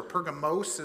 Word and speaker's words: Pergamum: And this is a picture where down at the bottom you have the city of modern-day Pergamum. It's changed in how they pronounce it, or Pergamos is how some others would pronounce it.